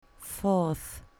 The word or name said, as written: fourth